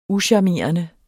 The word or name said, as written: ucharmerende